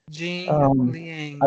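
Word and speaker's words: Jing Liang (景亮): And as for Lee, how do you say his name? Jiliang? Mm-hmm. I can't say it right. – Jing Liang.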